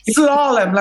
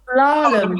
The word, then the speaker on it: Slalom: So you're in a band called Slalom D. I can't say it properly. – Slalom, like the skis. – Oh, Slalom.